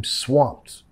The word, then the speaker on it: swamped: Uf, I'm swamped. Es decir, no, no puedo, tengo mucho ya, ¿ok? Nota acá la ED, vamos a pronunciar como una T. Swamped, swamped, I'm swamped.